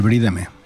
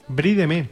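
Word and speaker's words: Brie de Meaux: Bueno, pues estamos aquí de vuelta de la tienda de queso más cercana y yo me quedo con el brídeme. – ¿Brídeme? – Brídeme. Tremendo. – Que es como queso brie. – Es que el brie de la zona de Meaux, que se escribe, y es una maravilla.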